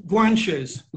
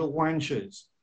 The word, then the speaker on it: Guanches: The Guanches. – Guanches people. And then there's another one of the, what is it, how do you pronounce that? The Tenerife, Well, the island, Tenerife. – Tenerife, yeah. – Tenerife, but then, you know, if you're Portuguese or Spanish, you say Tenerife. And also, which I found out when I was living on Tenerife, some of the people over there say the Guanches. because they pronounce the G-U as a W sound.